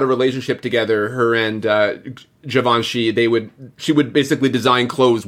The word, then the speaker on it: Givenchy: Although the costumes for Audrey Hepburn were Pierre Gavinci, which makes total sense. Mm-hmm. I don't know if I pronounced that right. Is it Gavinci? – I thought it was Givenchy.